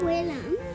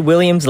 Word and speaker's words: Willems: I'm not Willems. – Yeah, I actually think it is Willems. I think I said it wrong last time. I said Williams last time.